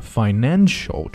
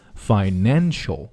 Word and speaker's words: financial: Mexico 注意它的口型呢开口都不是很大、啊、 Mexico 不是 Mexico、啊、 Mexico A Mexico City One of the financial 注意它的重音的位置在后面 Financial 不是 Financial